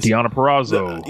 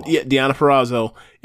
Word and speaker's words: Deonna Purrazzo: Deonna Purrazzo. – Deonna Purrazzo.